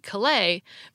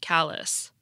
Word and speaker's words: Calais: John told me about a town in Vermont that's spelled like the French city Calais, but is pronounced Calais.